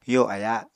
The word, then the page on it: yo aya: apatani